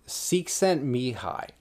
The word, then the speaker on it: Csikszentmihalyi: How do you say that dude's name? – I always thought it was Csikszentmihalyi. – It's Mihai. It's Csikszentmihalyi. I looked it up the other day.